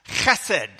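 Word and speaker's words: chesed (חסד): And the Old Testament was written in Hebrew, and that one word is pronounced chesed. You can clear your throat by saying that word.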